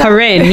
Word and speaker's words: Koren: Corin. Yes, Corin. – K-O-R-E-N.